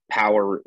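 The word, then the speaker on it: Powur: So Power is the name of the business that both Randa and I are involved with. Power is a renewable energy business.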